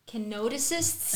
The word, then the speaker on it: kenosists: How do you say that word, babe? – Kenosists. – There you go.